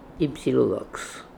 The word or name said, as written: Ypsylodox